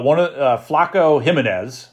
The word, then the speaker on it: Flaco Jimenez: Flaco Jimenez, I believe I'm saying his name right.